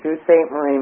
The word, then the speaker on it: Sault Ste. Marie: That would be Sault Ste. Marie, Michigan.